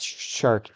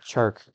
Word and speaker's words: Chark: Not Shark. Chark. – Chark. There you go, buddy.